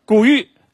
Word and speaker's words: 谷雨: 谷雨，有没有不同意见的？念谷雨，古人是怎么念的？有哪个小朋友知道古人怎么念的？啊，对了，我送你一本书，等会儿你到我领啊。古玉，对了，这个字，严格的来讲，作为节气里面，古人就念的是玉。